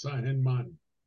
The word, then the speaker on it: San Germán: From the west side of the island, it's a town called, it's called San German, but in Spanish it's pronounced San Germán.